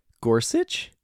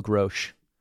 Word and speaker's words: Gorsuch: This is another one of those cases where there's a name that I clearly have been mispronouncing in my head for a very long time. – Which one? – Neil Gorsuch? – What were you saying? – In my mind, I call him Neil Grosch.